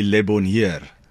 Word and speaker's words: Le Bonheur: Yeah, I think the South African culture, you know, speaking Afrikaans, we kind of pronounce it the way we read it. – Okay. – And that would be Le Bonheur.